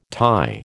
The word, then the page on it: tie: American English